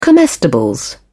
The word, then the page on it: comestibles: British English